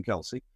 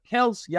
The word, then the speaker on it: Kelce: Se debería pronunciar Kelce, ya hasta ellos han aceptado Kelce, porque todo el mundo lo pronuncia así, y se dieron por vencidos, Se dieron por vencidos. Entonces ya, nos quedamos con Kelce entonces.